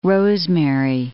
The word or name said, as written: rosemary